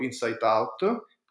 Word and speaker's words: InsightOut: che è cofondatrice insieme a Valeria del progetto Insight Out,